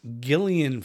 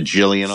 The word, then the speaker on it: Gillian: and I didn't know this is how you pronounce it, Gillian Flynn's Gone Girl. – So we called it Jillian all these years.